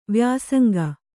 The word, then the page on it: ವ್ಯಾಸಂಗ: vyāsanga